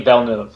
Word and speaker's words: Villeneuve: So how does he say his name? – Denis Villeneuve. – Villeneuve. I mean, I say it as Villeneuve based on the Formula One name. Obviously, the great Gilles and Jacques Villeneuve.